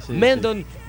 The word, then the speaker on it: Mendon: Mendon, essa é a pronúncia? – Mendon.